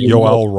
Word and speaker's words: Yoel: There's this gentleman, I've never heard his name said, Yoel, maybe is how it's pronounced. – Yeah, it's Yoel Roth, I believe, right? – Yoel Roth, yeah.